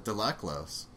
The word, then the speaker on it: Laclos: I would say read Dangerous Liaisons by Gerardos de Laclos. I guess you don't pronounce the final S,